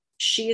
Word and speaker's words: sidhe: She is the Irish word for fairy. It's spelled S-I-D-H-E.